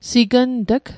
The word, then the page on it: signt'g: sikn'tîk